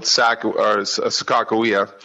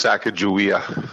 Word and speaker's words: Sakakawea: Everyone here calls it Sakakawea, but Sakajawea would be the way you'd pronounce her native way. So usually it's people from out of town or not of the area that do this Sakajawea.